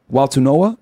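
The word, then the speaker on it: Nauta: And then his assistant, the guy that they indicted, Waltunoa is how it's pronounced, if I'm not mistaken.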